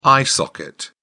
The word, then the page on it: eye socket: British English